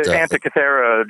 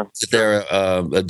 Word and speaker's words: Antikythera: I mean, you know, sure, we have the Baghdad Battery and the Greek, I'm not sure how to pronounce it, but the Antikythera device is what you're talking about. – Yeah, Antikythera device.